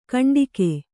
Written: ಘಂಟಿಕೆ

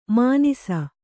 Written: ಮಾನಿಸ

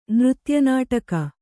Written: ನೃತ್ಯನಾಟಕ

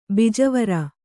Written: ಬೀಜವರಿ

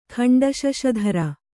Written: ಖಂಡಶಶಧರ

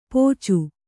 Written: ಪೋಚು

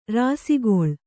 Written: ರಾಸಿಗೊಳ್

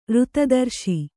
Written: ಋತದರ್ಶಿ

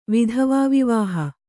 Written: ವಿಧವಾವಿವಾಹ

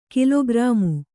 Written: ಕಾಲಕರ್ಮ

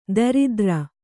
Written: ದರಿದ್ರ

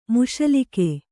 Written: ಮುಸಲಿಕೆ

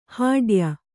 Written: ಹೂಡೆಯ

